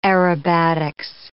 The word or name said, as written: aerobatics